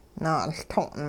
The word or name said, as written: naa'uLto'n